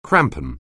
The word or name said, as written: crampon